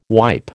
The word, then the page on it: wipe: American English